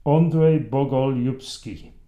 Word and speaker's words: Andrei Bogolyubsky: Okay, so that's the end of Uri, but I'll continue the story through the arc of his son, whose name about 5 minutes ago I completely butchered, so let's have another go. Andrei Bogolyubsky.